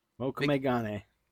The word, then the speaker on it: Mokume Gane: Mokume Gane. – Mokume. I'm never going to say it right. I'm just going to stop. But I've Mokume. – Seen it. – Mokume. Mokume.